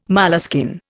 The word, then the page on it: moleskin: Indian